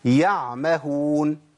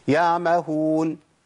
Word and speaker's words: يعمهون: يعمهون، انا قلتها صح كده، اسمعوا كده وشوفوا العين هتختفي ازاي. يعمهون يعمهون يعمهون، انا كده ما جبتش عين خالص، انا اسقطت حرف من القرآن وجبت حرف بديل ليه ما اعرفش ايه هو.